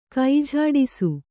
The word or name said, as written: ಕೈಜೋಡಿಸು